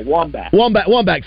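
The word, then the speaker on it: Wambach: Hamback or Hamback. I can't know how to pronounce it. I should know that. – Oh yeah, Wombach. – Thank you. I was a couple letters off. Thank you.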